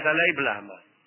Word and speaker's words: Dalai Lama: Así como Dalai Lama en realidad es Dalai Blama.